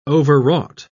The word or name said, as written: overwrought